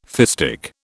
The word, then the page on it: fistic: British English